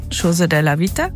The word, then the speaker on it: Cose della Vita: Ich habe hier noch Cose della Vita Aussprache. Chose della Vita.